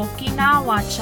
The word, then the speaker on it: Okinawa: Today's show, she's in Grandma, how do you say it? – Okinawa, child.